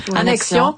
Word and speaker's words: annexion: Je pense que c'est le premier, et c'est tout à son honneur, de se lancer sur ce que ça pourrait être, une annexation, sais-tu? – Annexion. – Trop de syllabes.